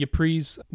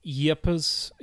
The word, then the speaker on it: Ypres: Yepers or Ypres, Ypres, we call it that because that's the French name, but the people who live there is like Yepers.